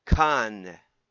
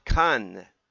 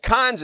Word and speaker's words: Cannes: I did actually listen to about a dozen of these, and it's pronounced by the French. Cannes. With a little E at the end, but very soft. Cannes, like that. That's the right way. But cons is the way English people say it. So go for it. Either way you like.